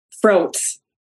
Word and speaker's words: Froats: Froats. – Froats. – Like yes. Froats with fro in front, like FR.